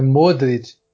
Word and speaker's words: Modric: É Modric, Rakitic. A sílaba tônica é sempre a primeira desses croatas aí.